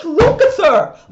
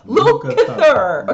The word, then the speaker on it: Lukather: You should have kept it to Paul Jackson Jr., Steve Luke, like, why can't I pronounce Steve Luke? I pronounced it fine when we were watching it. Steve Lukather. Lukather.